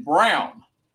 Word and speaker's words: Braun: It's pronounced, according to Mike Breen, Christian Brown. But it's spelled, guys. This guy, this rookie out of Kansas, his name is spelled B-R-A-U-N.